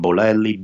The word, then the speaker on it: Bolelli: So D-A-N-I-E-L-E. My last name, Bolelli, B-O-L-E-L-L-I, again, B-O-L-E-L-L-I.